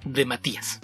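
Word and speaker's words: DeMatteis: Entonces, durante mucho tiempo, y recuerdo que hay mucha gente que le decía de Matiz, de Matiz, de Mateis, y es que eso es la forma en que se escribe de Mateis, pero de acuerdo con el propio autor, la pronunciación correcta es de Matías.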